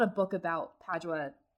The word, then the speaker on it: Padua: I keep wanting to call her Padova, which is how you say it in Italian, but Padua.